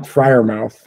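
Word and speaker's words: Freiermuth: Fryermuth? How do you say his name? – Pat Fryermuth.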